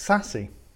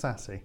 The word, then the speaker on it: SASE: Now, SASE or SACE, well, I'd love to find out how it's pronounced. – It's SASE. – SASE. – Yes, okay. I did an episode with Tom McVeigh from Menlo, and it is pronounced SASE.